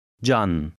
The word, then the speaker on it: Can: The Turkish letter that looks like a C has a J sound, as in the English word jar. Try to read number 15. – Can.